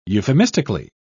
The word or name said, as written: euphemistically